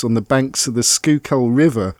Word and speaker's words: Schuylkill: And that name, Schuylkill, the way it's spelled S-C-H-U-Y, kind of shkui, and then kill.